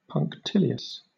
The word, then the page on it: punctilious: British English